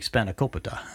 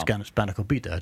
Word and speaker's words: spanakopita: And they taught Seal how to make spanakopita. – Oh, wow. – Or spanakopita, depending on how you say it, which is a spinach pie, basically.